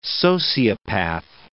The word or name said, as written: sociopath